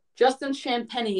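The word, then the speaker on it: Justin Champagnie: Justin Champagne is the official pronunciation.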